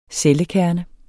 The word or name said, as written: CELLEKERNER